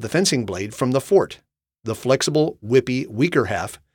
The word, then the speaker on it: forte: Nowadays, most of us mispronounce this word by saying forte, as in, boring listeners with podcasts about word origins is my forte. This pronunciation is used in music, where it is the correct pronunciation and it means to play loudly.